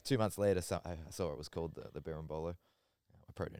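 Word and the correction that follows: berimbolo: You pronounce it Barimbolo.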